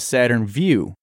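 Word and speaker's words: Saturn Vue: I love you, but I got the giggles every time you said, quote, Saturn VU in the Organized Criminals episode. She says, I assume you mean a Saturn VU, SUV, pronounced like the word view.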